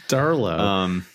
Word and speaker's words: Darlow: I mean, starting with the defense, no one priced below 5.5 this side of Darlow or Darlot. I don't know how you pronounce that, but I don't care. We're not going to put him in our FPL teams. – Darlow. – Darlow. I knew I'd get corrected either way.